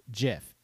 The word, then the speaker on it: GIF: It is a soft G pronounced GIF. End of story.